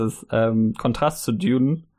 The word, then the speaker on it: Dune: Ich will das immer im Englischen mit so einem DJ aussprechen, so ein Dune, aber es stimmt ja gar nicht. Dune. Ich glaube, der heißt einfach nur, Dune. der heißt einfach nur Dune, wenn Dune. ich mich nicht irre.